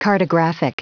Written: cartographic

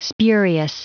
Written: spurious

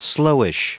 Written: slowish

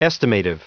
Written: estimative

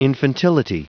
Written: infantility

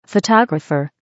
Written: photographer

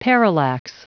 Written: parallax

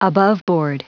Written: aboveboard

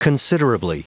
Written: considerably

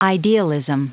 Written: idealism

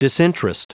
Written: disinterest

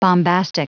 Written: bombastic

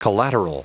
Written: collateral

